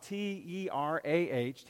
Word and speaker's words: Terah: There, a man named Terah, T E R A H, Terah, lived with his family and he had three sons Abram, Nahor, and Haran.